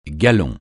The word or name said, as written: galon